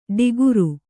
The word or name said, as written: ಡಿಗುರು